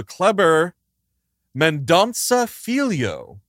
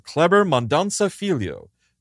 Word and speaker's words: Kleber Mendonça Filho: So Kleber Mendonça Filho is how you say his name. So Kleber Mendonça Filho.